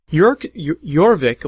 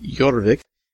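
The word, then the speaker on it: Jorvik: Jurvik, or it's J-O-R-V-I-K, that's how you pronounce it, Jurvik, is a re-implementation, reimagining of an early Stefan Feld game.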